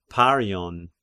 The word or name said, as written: Parion